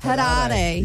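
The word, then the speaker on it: Harare: Which landlocked African country has Hariri? I don't know if I'm saying that right. – No. – It sounds like you are. – Hariri.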